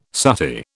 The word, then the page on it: suttee: British English